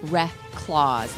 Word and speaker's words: Reklaws: Don's, see, but if you just spell it the way it's spelled, R-E-C, no, R-E-K-L-A-U-S-E, whatever it's spelled, you have to spell it phonetically. Rek, W-R-E-C-K-Laws. That's the way it's pronounced. – Reklaws, like recluse, but Reklaws? – I don't know what it means.